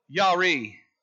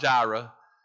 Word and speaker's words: Yireh: It's Jehovah Yireh. Yeah, it's not Jireh. We say Jireh in our English vernacular. But it's Yireh.